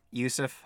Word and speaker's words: Yousef: Yeah, you can find me on Twitch at Yousef Hatlani, one word, that's spelled Y-O-U-S-E-F.